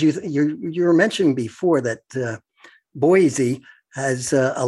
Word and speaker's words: Boise: But when you live here, you pronounce it B-O-Y-S-E-E, Boise.